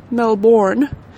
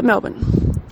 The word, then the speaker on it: Melbourne: Not Melbourne, as we have constantly been hearing, to a shudder down my spine, but Melbourne. Alright, it's Melbourne, guys. You can't pronounce it, okay? You gotta pronounce it as if there are no vowels in this word whatsoever, and you're just trying to smush the whole thing into one syllable, okay?